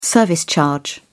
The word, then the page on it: service charge: British English